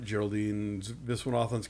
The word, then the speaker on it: Viswanathan: Viswanathan. – I'm always wrong on which syllable. Viswanathan.